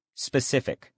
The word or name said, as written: specific